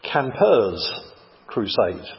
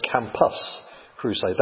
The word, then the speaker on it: Campus Crusade: You have to be careful with the pronunciation. When I first heard it, I imagined people living in tents. Campers Crusade. No, it's Campus Crusade. They changed their name about 8 years ago to Cru, C-R-U.